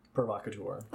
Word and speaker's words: provocateur: Provocateur.